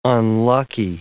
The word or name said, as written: unlucky